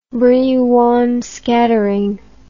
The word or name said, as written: Brillouin scattering